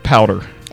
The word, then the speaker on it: Powter: It's pronounced Powder.